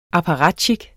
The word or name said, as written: apparatjik